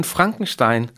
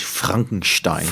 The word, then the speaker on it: Frankenstein: Så, da hun, så hendes roman Frankenstein, øh, den er faktisk sat, den foregår faktisk i, i Ingolstadt. – Det skal rent faktisk udtales Frankenstein. Frankenstein, og det er jo lidt foregår i Bayern.